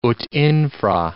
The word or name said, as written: ut infra